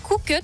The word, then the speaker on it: Cookut: C'est le concepteur et fabricant d'ustensiles de cuisine à petit prix Cookut, si je prononce bien.